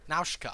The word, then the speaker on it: Nausicaa: You're pronouncing it wrong, Mike. – Yeah. – What? – Nausicaa.